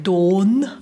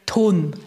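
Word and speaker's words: Ton (돈): Wenn wir das koreanische Wort für Geld so aussprechen würden wie im Deutschen, würden wir sagen Don. Das klingt total komisch auf Koreanisch. Man sagt Ton ein Ton, bisschen stärker.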